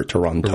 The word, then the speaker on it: Toronto: While you're finding that, my point was that I'm trying not to pronounce the hard T in toe for Toronto.